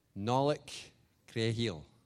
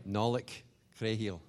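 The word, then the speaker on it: Nollaig Chridheil: So it's pronounced Nollaig Creahil. – Okay, Nollaig Creahil. Is that right? – Nollaig Creahil.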